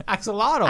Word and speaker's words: axolotl: Everyone's getting an axolotus. – Axolotl. – Axolotl. – I just said it. You said it wrong again.